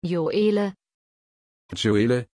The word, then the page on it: Gioele: German